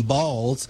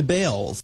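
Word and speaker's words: Baals: After all, the family did change the pronunciation of their last name from Balls to Bales. – They did. – He spells it differently, though. – It's B-A-A-L-S.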